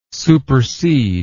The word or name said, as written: supercede